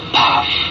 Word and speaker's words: Pfaff: P-F-A-F-F spells Pfaff, pronounced in Thai, Paff.